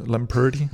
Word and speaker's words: Lamperti: Jeg ved faktisk ikke, hvordan fanden man udtaler ham. – Lamperti.